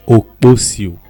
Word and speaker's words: Okposio: Okposio.